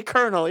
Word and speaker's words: colonel: But you say colonel. You're looking at it, motherfucker, who are you fucking with? – Are you playing? You fucking with me? – It's one of those fucking silent L's that sound like R's.